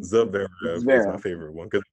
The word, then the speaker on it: Zverev: So as we see with the men's final, that took a little over 4 hours, featuring Alexander Zverev. I didn't think I was going to butcher his name like that. His name is Alexander Zverev.